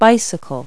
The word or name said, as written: bicycle